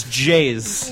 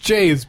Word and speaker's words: Jayz: That's spelled J-A-Y-Z. – Yeah. – Jays. – Keys. Now. – Wait a minute. It's J-Z? – No, it's Jays. – It's Jays. – Yeah, I know it's pronounced Jays, but it's still J-A-Y-Z.